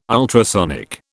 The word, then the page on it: ultrasonic: British English